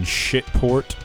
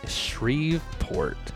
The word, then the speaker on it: Shreveport: The pronunciation in Louisiana. – Call me crazy. Shreveport.